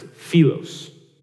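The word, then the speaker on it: philos (φίλος): It's pronounced philos.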